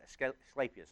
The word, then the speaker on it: Asclepius: That's how you say it. Asclepius.